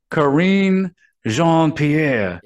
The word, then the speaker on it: Karine Jean-Pierre: But yeah, that's and how's her name pronounced? – What is it? Jean? – It is Karine Jean-Pierre.